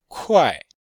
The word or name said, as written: kuai4